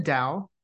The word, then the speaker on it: Tao: Yeah, so Seeds of Tao mm-hmm. is our website, and it's spelled like the Tao Te Ching. So it's not like D-A-O, it's T-A-O, because that's how you pronounce it.